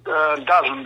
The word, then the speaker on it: DAZN: Това нещо да, ново за първи път матч от такава величина ние ще следим онлайн чрез платформата DAZN. Правилно ли я произнасям? – DAZN, да. Иначе и казват DAZN. – DAZN, DAZN, да. DAZN така е популярно. – Е правилното произношение, да.